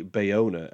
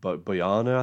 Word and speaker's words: Bayona: Bayona, and just a quick one, I don't know why I always get Bayona's name wrong. I called him, I think, Boyana, I think, on my Jurassic World podcast.